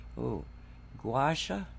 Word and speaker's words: Gua Sha: Gua Sha, is that how it's pronounced? – Yes.